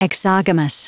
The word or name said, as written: exogamous